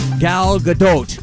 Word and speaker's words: Gal Gadot: Number 4, Gal Gadot.